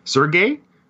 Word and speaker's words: Sergei: Sergei Stallone. – Now, you say Sergei. I looked high and low for Stallone talking about him. – I said it wrong. Okay. Sergei.